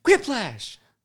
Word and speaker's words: Quiplash: Just remember how they say it in the game? Quiplash!